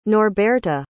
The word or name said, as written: norberta